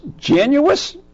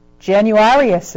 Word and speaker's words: Januarius: Januus? How do you say that? Januus? You can't see. – Tag your finger over it. Januarius is. – Januarius. – Januarius? – Yep.